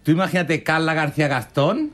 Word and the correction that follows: Gascón: Gascón. – ¿Cómo se dice? – Gastón era el de La Bella y la Bestia. Gascón.